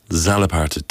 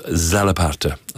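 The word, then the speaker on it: txalaparta: Zalaparta, T-X-A-L-A-P-A-R-T-A. I think it's pronounced Zalaparta, and it means a racket, or it can also mean the trot, the pony trot of a horse, that kind of rhythm.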